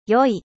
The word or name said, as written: 良い